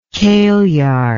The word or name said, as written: kailyard